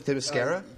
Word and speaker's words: Themyscira: I am your co-host, and joining me, the first man to step foot on Themascalis. Did I pronounce it right this time? Themyscira? – You were very close.